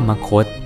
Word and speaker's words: มคธ: สวัสดีครับพบกับรายการคำวัดวันนี้ขอเสนอคำว่ามคตคำว่ามคต